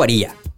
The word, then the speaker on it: Aria: Yo le digo aria. – Pero ellos lo pronuncian Aria.